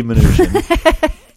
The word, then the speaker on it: Mnuchin: Hard to pronounce his name sometimes. Almost right. – Like Munchin, but Munchkin. Steve Mnuchin is a new Treasury Secretary.